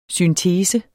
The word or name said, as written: syntese